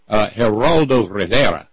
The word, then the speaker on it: Geraldo Rivera: Geraldo Rivera. Went, excellent pronunciation, by the way.